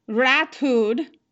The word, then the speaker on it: Rathod: And so we looked up how to say it, and it was pronounced online as Ratud.